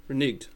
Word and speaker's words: reneged: Reneg. – Reneged. Yeah, that's. – We've got part of my culture. – No, that's not how you say it.